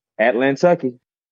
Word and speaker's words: Atlantucky: Is it Atlantucky Brewing? – Atlantucky.